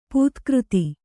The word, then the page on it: ಪದಾಘಾತ: padāghāta